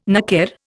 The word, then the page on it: naker: Indian